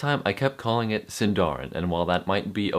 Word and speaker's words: Sindarin: In Sindarin, you put your emphasis on the third to the last syllable if your word has more than three syllables. Sindarin happens to have three syllables, so unlike what I was doing last time, putting the emphasis on the dar, you're going to put it on the sin in.